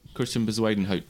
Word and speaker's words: Christian Bezuidenhout: Christian Bezuidenhout.